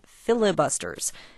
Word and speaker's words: filibuster: Eventually, an extra syllable wormed its way into the word, and flibutors became filibusters.